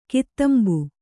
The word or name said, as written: ಕದಂಬ